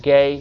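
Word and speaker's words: Ge: G.E. is how you say his last, spell his last name. Gay, G.E.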